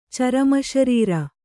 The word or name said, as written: ಚರಮಶರೀರ